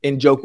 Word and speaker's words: Njoku: I'm not sure how to pronounce the tight end's name the right Njoku. – Way. Njoku.